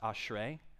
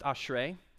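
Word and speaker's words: ashrei (אשרי): This is the Hebrew word for it's pronounced ashrei. I think this will be on the screen. Yeah. It's pronounced ashrei. Can you guys say that with me?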